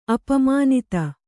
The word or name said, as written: ಅಪಮಾನಿತೆ